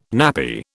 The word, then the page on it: nappy: British English